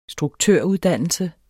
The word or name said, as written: struktøruddannelse